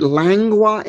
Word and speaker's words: lengua: Lingua, I think in Spanish, isn't it lengua in Spanish? – Lengua. – No, it's lengua. – No, it's lengua. – How do you spell it? – L-E-N-G. – Yeah, L-E-N, not L-I-N.